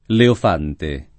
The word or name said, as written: leofante